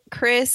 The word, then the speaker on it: Kris: There's actually one with my name on it, Chris, spelled the same way I spell it, K-R-I-S.